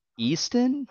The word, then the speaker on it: Easton: Eatson. Am I saying that right? Eatson. Eatson. Eatson? – Yeah. Easton? Easton, thank you.